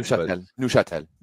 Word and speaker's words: Neuchâtel: Budovani Woj, who had an okay Champions League campaign on some team called Neuchâtel from Switzerland. I have no idea if I said that even close to right. – Neuchâtel. – Oh, so it's like French pronounced.